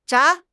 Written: 眨

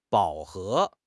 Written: 饱和

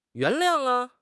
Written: yuan2 liang4

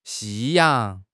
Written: 夕阳

